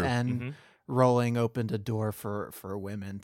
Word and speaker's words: Rowling: So, like, well, and also to give Rowling and Meyer and everybody some credit. I think I'm mispronouncing. It's Rowling Rhymes with Bowling.